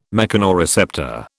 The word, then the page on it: mechanoreceptor: British English